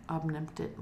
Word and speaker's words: omnipotent: How did you say that? – Omnipotent. – Omnipotent. I yeah. know how to spell it, but I couldn't say it for some reason.